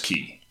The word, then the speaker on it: qui: Q-U-I tam. – Right. Q-U-I tam. – Latin. – Right. But it's pronounced key. – I thought it was pronounced quietam. But it's, quietam? – Well, again, pronunciation. Go with key.